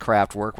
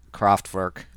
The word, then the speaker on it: Kraftwerk: Typically, when you hear that type of drum loop, you're expecting something like Kraftwerk, where they come in, or Kraftwerk. I know, I pronounce it like an American.